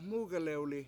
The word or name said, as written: MUUgalewli